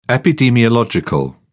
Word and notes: epidemiological: British English

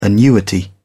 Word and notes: annuity: British English

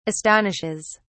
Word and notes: astonishes: British English

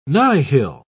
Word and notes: nihil: British English

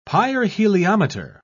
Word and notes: pyrheliometer: British English

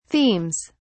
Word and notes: themes: British English

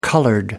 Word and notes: coloured: American English

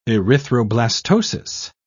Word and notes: erythroblastosis: British English

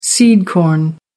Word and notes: seed corn: American English

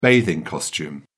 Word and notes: bathing costume: British English

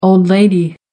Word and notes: old lady: American English

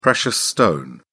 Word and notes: precious stone: British English

